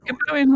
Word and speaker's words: Gobowen: The Gobowen Hoard, a Roman hoard that you discovered? – Gobowen Hoard.